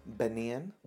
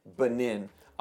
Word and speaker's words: Benin: So we're here, obviously, we've been talking to brother and sister from Bogalusa, Louisiana, but now you serve as missionaries in, help me pronounce the name. – Benin. – Benin. I always want to say B9 or something like that, but Benin, West Africa.